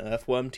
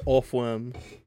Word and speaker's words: Orthworm: Yeah, so one of my, on my second cut, my team is an Earthworm team, and... – Say it right. – It's Orthworm.